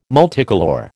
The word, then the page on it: multicolour: American English